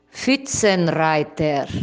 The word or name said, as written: Pfuetzenreiter